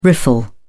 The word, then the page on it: riffle: British English